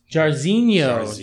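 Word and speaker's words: Jairzinho: Let's start off with Junior Dos Santos versus Yair Rozenstrike. I feel like I could say that guy's name five different times. – You said his first name wrong. – Yair? – It's Jarzinho.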